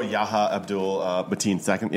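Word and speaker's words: Yahya Abdul-Mateen II: Oh, Yahya Abdul Mateen II. Yeah. – There you go. That's how you pronounce it the first time.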